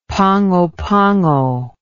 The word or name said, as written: pango pango